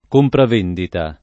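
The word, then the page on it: compravendita: italiano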